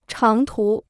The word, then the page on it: 长途: cháng tú